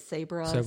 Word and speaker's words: Sabra: How do you say it? Sabra. – Yeah.